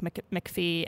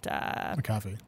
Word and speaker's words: McAfee: He basically said that his competitors being incumbents like McAfee and McAfee. – Yes, I never pronounce that one right.